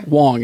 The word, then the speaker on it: Wang: Wang is how you spell it. Find Wang. Out what he's doing. Look on IMDb for Garrett Wang. – Okay. – Wong is how you say it.